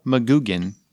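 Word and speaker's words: McGuigan: There are actually 2 interviews in the feed right now with the director, Paul McGugan. And I butcher his name because it's hard to say because he's got like 2 G's. Paul McGugan. There. Hey, I did it.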